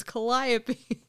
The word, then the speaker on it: Calliope: and she said Calliope, it's like cantaloupe, like word for word what I said, it's like cantaloupe, so it's Calliope.